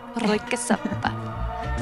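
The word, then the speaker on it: Röyksopp: I'm Kirstine McKenzie, and this is Royksop. am I saying it wrong? Rökesoppa. Does that mean anything in Swedish? Soggy rag or something? – Rökesoppa. Yeah, smoke soup, I guess. But it's Norwegian.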